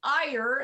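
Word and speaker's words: Er: It's called E-R, but it's actually Ier. And I had to learn that as well. It's just the Hebrew pronunciation. It's just the way it would be pronounced in Hebrew.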